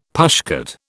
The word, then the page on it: pushcart: British English